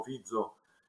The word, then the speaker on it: Fico: per la vittoria di Robert Fico, anzi come, come si pronuncia in slovacco Fizzo,